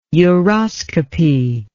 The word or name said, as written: uroscopy